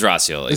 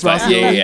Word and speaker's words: Roscioli: Did I say that right? – It was Roscioli. It's Roscioli. Yeah. I wasn't going to correct you.